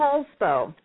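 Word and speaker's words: Poulsbo: She lives in, how do you say this, Polsbo? – I live in Polsbo.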